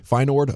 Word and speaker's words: Feyenoord: If you are looking for some afternoon sports to watch, is it Feyenoord? How do you pronounce that? – Feyenoord, okay.